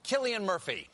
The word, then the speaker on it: Cillian: Please welcome Cillian Murphy.